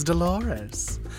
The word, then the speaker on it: Dolores: You all know me as Dolores. Rhymes with clitoris.